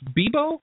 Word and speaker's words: Bebo: It came from, I think it's Bebo. Is that how you pronounced it? – Yes, Michael Birch.